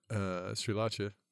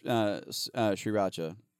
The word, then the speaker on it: Sriracha: Sriracha. Sriracha. – Sriracha? I thought it. – Sriracha. – I've always kind of pronounced it Sriracha, but apparently that's wrong. So like Sri Lanka.